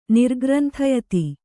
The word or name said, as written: ನಿರ್ಗ್ರಂಥಯತಿ